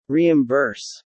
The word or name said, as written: reimburse